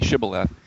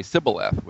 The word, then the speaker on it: Shibboleth: And only a Gileadite could say it properly, Shibboleth. And the others would say Sibboleth with an S sound.